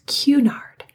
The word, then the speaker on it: Cunard: Cunard, is it? It's Cunard. Guys, I know that's the proper way to pronounce it, but my brain just does something differently.